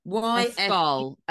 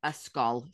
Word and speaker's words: Ysgol: Yosgol Friars? – Yosgol. Yosgol. Is it Y-S-G-O-L? – Yes. – Yosgol. School, that is.